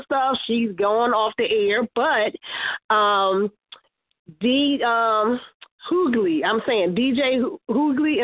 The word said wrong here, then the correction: Hughley: DL Hughley, the comedian.